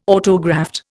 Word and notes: autograft: Indian

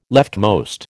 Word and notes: leftmost: American English

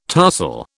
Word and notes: tarsal: British English

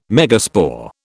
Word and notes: megaspore: British English

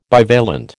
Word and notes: bivalent: American English

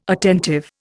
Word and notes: attentive: Indian

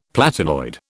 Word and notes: platinoid: British English

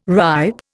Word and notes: ripe: Indian

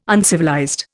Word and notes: uncivilized: Indian

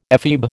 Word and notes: ephebe: American English

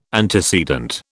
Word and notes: antecedent: British English

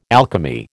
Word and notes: alchemy: American English

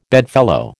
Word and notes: bedfellow: American English

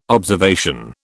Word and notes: observation: British English

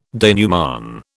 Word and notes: denouement: British English

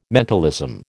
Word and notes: mentalism: American English

